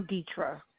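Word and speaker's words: Deitra: I'm Deetra.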